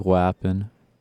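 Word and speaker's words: Wha'ppen?: How would you pronounce this? Is it just What Happening or Wappin'? Wait, say it again. – I would say like Wappin'.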